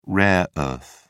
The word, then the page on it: rare earth: British English